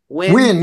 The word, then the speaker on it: Nguyen: How do you say if it's Ngyn? You know how something like how do you pronounce that? Win, right? – Win. Yeah, that's how you pronounce Win.